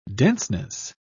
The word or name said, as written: denseness